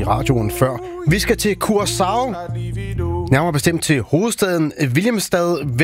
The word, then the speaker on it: Curaçao: Ja, hvis du må tage det helt korrekt, så siger det, hedder Curaçao. Det der C med den lille krølle under, det får sådan en sjav-lyd, men langt de fleste, de udtaler blot som et SC, så Curaçao.